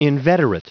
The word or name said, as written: inveterate